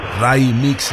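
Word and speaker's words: Raymix: Cuando se trata de mexicanos o de latinos que viven en Latinoamérica, pues el Raimix está correcto, así se pronuncia.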